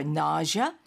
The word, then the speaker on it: nausea: Do you have anything for nausea? 자, 이 nausea라는 표현을 꼭 알아야겠네요. – Nausea, N-A-U-S-E-A, nausea. – Nausea. – Nausea. – Nausea. – Yeah. 그 S 발음이 je, 뭐 pleasure 할 때, vision 할 때.